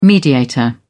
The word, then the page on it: mediator: British English